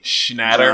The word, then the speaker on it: Schnatter: So last week it came out that John, I can't pronounce his last name, so I'll just say Schnatter.